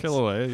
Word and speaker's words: Kilauea: Kilauea, you said it right.